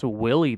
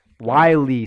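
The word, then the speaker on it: Wile: It's not pronounced Willie the Coyote, is it? Uh, Wiley, son.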